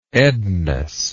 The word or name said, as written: ednas